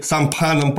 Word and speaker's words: Самфан: Причем забегая вперед, скажу не просто так, со своими будущими друзьями и соратниками Йенгом Сари, Кео Самфаном, точнее, Самфаном, правильно говорить, Самфан – это очень сильно русифицированное произношение, он Самфан, вот так вот,